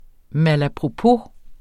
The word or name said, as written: malapropos